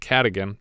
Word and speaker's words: Cadogan: I would have pronounced it Cadogan, but everyone here says Cadogan, so I'm going to go with them.